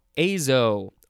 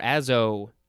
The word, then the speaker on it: azo: We are going to move on to azo or azo, and it is spelled A-Z-O.